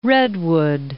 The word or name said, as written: Redwood City